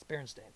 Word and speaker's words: Berenstain: But it was, it's not. It's Berenstain. So. – Weird to me. – But I think when I was a kid, it was mostly pronounced Berenstain. Berenstain is how I always heard it pronounced. – I feel like I heard both. I don't know. But yeah, never Stein.